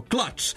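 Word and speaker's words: Clutch: Idemo na slovo C, stigli smo do slova C, a tamo, kako se izgovara K, tako, klač.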